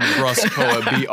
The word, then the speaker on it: BruskPoet: And by the way, my name is spelled BruskPoet, BRUSK, not Brusk.